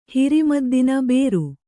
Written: ಬೇರು

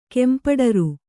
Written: ಕೆಂಪಡರು

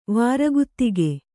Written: ವಾರಗುತ್ತಿಗೆ

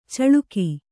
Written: ಚಳಿಕೆ

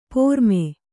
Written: ಪ್ರಮೆ

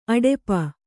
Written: ಅಡಪು

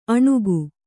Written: ಅಣುಗು

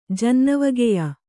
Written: ಜನ್ನವಗೆಯ

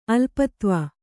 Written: ಅಲ್ಪತ್ವ

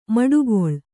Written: ಮಡುಗೊಳ್ಳು

